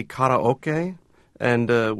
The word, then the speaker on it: karaoke: What's the right way to pronounce the word karaoke? – Well, it's actually karaoke, which is a Japanese term that combines the words empty and orchestra.